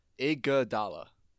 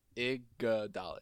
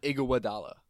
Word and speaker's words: Iguodala: It's, how many syllables? Iguodala. Iguodala. It's not Iguodala. There's no f***ing Iguwa.